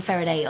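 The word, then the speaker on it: Feraday: At Caroline Faraday on Twitter. I think it's Caroline _ Faraday. I'm F-E-R, by the way. A-D-A-Y, which now everyone spells my name wrong.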